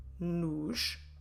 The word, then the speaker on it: nos: So what happens is that u, a, ush, ash will change into nu, na, nush, nash. How do we spell? Como se escreve nu, na, nush, nash? Nu, n-o. Na, n-a. Nush, n-o-s. Nash, n-a-s.